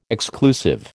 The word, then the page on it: exclusive: American English